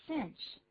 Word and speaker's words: cinch: 但那个 cinch 那个发音还不太好念，不要念 cinch 啊，怎么念的呢？Cinch. Cinch. 注意这个 i 的发音，一样是写成 i。 i 有时候念作 e，有时候念作 i。那当它念作 i 的时候，你只要想到行军的时候，在那个 i。英文的短的 i 的声音就是这样子 i。